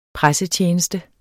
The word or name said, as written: pressetjeneste